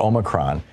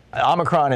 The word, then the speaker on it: Omicron: It's titled, "You Will Get Omicron," or Omicron. You hear both pronunciations these days. I think the long O is the formal one, the correct one, but the popular one is the short O.